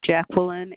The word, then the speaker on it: Jacqueline: Jacqueline Anderson.